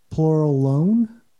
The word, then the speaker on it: Pluralone: It's spelled plural and then one, but I think he pronounces it as Pluralone. So it almost sounds like you're alone.